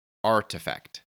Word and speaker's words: RTFKT: By now, you've probably already heard that Nike acquired RTFKT, which is pronounced Artifact, the crypto collectibles company that was founded only in 2020 to create collectible digital assets like sneakers.